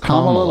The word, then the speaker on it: Kamala: It's Kamala. So it's Kamala like Kami, not Kamala like Camellia.